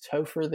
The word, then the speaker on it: Topher: It is Topher the Guru.